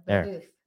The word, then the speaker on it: Babeuf: Babeuf.